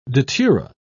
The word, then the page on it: datura: British English